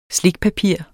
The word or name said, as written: slikpapir